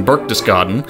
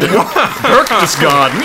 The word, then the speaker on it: Berchtesgaden: He thinks that the hitman is in Berchtesgaden. Did I pronounce that right? – I think you did. – Oh my god! Berchtesgaden!